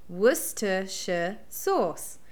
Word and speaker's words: Worcestershire: So the proper way to say it is Worcestershire sauce, and it's from Worcestershire.